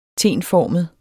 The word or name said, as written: tenformet